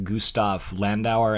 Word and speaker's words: Gustav Landauer: Yeah, in your biography, I was really struck in particular by his friendship with Gustav Landauer.